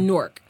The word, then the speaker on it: Newark: We say it like fork with an N. – Yeah. – It's Newark.